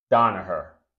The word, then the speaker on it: Danaher: Pardon me if I pronounce it, mispronounce it, but it's Donaher, right? D-A-N-A-H-E-R, Donaher Corporation.